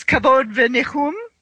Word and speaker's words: Kavod v'Nichum: It is Kavod Venichum, is how you pronounce it? – That's correct.